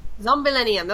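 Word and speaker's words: Zombillénium: I believe I'm pronouncing that right. It's Zombillionium. It's Zombillionium. – Very... Zombillennium. Okay, that's how you say it.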